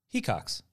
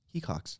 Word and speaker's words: Hecox: Hecox. – Okay. – Yeah. – I don't know why I thought otherwise, but... – What did you say? – Hecox.